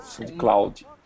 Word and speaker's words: SoundCloud: SoundCloud, não lembro como é que fala. – SoundCloud.